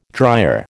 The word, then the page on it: dryer: American English